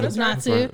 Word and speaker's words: Natsu: Natsu or Kazu. – No, Natsu.